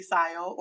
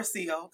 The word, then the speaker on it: Sio: So my name is Siobhan, but everybody calls me Sio or Sio because nobody actually knows how to say my name the way it's spelled.